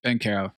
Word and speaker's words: Banchero: Maybe the NBA is so corrupt now that he'll represent Paolo Banchero. Am I pronouncing that right? – Banchero. – Good enough.